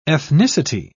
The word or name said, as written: ethnicity